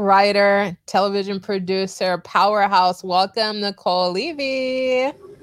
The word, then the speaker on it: Niceole: And for everybody, it is pronounced Niceole, even though it's spelled weird.